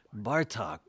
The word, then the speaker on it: Bartók: And interesting to think of that as a Hungarian rhythm in music, because it's almost like Hungarian language, where the emphasis is always on the first syllable, like Béla, Bartók, Zoltán, Kodály.